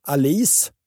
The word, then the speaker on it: Alice: Vi har varit inne på det här i samband med att eh, vi diskuterade varför Alice, hon ska uttalas så, Alice Tegnér könade ekorren i granen.